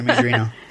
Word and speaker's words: Majorino: No, that was Tina Majorino.